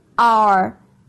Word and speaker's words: our: 有没有发现同样都是 O 作为开头，他们两个 O 的发音并不一样。首先看第一个 our, our, 在这边的 O 的音呢是 R, our, our 的音。